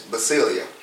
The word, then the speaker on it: basileia: So I mentioned last week, I went over the Hebrew meaning for kingdom in the Bible, and it's basilia, right? So it's spelled, for those taking notes, B-A-S-I-L-E-I-A.